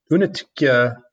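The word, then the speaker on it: Únětice: It's from a brewery called Unetitia Pivovar, or Unetitia 10 is what you see on the when you order it. And it's spelled Unetice, mm-hmm. is how you spell it.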